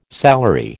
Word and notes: salary: American English

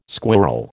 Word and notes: squirrel: American English

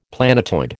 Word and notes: planetoid: American English

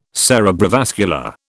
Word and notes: cerebrovascular: British English

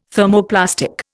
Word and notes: Thermoplastic: Indian

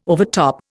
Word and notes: overtop: Indian

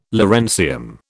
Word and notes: lawrencium: British English

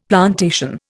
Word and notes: plantation: Indian

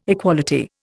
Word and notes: equality: Indian

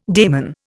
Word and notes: daimon: Indian

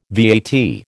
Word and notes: VAT: American English

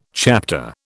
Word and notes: chapter: British English

